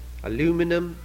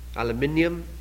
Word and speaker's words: aluminium: You call it aluminum, it's rightly called aluminium.